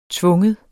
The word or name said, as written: tvinge